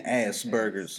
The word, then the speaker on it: Asperger's: Am I saying it right? Asperger? Asperger. – I thought it was Asperger's. – Huh? – Asperger's. – Asperger's. – Yeah. – Like as? Like as. – Yeah, as.